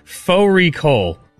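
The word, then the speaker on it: fårikål: Fori Kol, which is a mutton and cabbage. It's spelt like Fari Kol.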